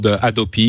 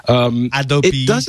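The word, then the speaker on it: Hadopi: By the way, it's called Adopi. You don't pronounce the H. – Adopi.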